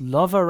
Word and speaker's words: Luva: Auf Luva, wie spricht man es aus? Lover Records rausgekommen. Luva geschrieben Records.